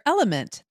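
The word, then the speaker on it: LMNT: Sarah, we're welcoming our sponsor, Element. That's spelled L-M-N-T, a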